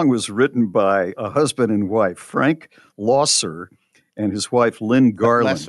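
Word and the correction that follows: Loesser: Lesser. – Yeah. Oh, is that how it's pronounced? – That's how it's pronounced. – Yeah. Losser. Lesser. Okay. Thank you.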